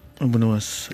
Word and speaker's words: Abou Nawas: Pendant quelques années, enfin, vous avez aimé chanter les grands poètes, évidemment, Rumi, Al-Hajjaj, et puis Abou Nawak. – Abou Nawas, je ne prononce pas très bien.